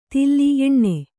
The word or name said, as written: ತಿಲ್ಲಿ ಎಣ್ಣೆ